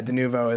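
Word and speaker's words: Denuvo: De novo, is that how you pronounce that? – Yeah, de novo is the name of the piracy.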